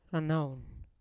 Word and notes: ranaun: Yawa

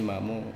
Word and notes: mamo: Wamesa

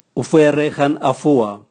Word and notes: uvwere gan avua: Raga

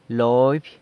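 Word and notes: loby: San Lucas Quiaviní Zapotec

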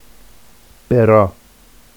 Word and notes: berɑ: Vafsi

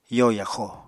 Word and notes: yo yaxo: apatani

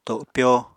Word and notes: topyo: Apatani

